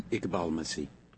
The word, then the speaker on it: Iqbal Masih: Iqbal Masih.